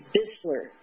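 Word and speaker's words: Buesseler: Did Buessler, by the way, is it Buessler? – It's Bissler. – It's Bissler. Okay, I've been mispronouncing it all the time.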